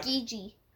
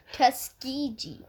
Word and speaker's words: Tuskegee: I hope I pronounced it correctly, Tuskegee Airmen. Yep. The nation's all black. Tuskegee. – Tuskegee? Okay. – Tuskegee. – Okay, well, I'm glad you got it.